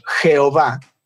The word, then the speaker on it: Jehová: Por eso le doy crédito a Casiodoro de Reina, que en su versión, él preservó ese nombre, está escrito como Jehová, pero la J no se pronunciaba de esa manera en el español medieval. En Europa se pronunciaba como una Y, como nuestra I.